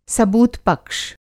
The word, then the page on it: सबूतपक्ष: सबूत-पक्ष